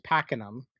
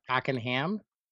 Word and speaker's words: Pakenham: Well, close. It does rhyme with the last. My last name is Pakenham, but some Oh. – people call it Pakenham.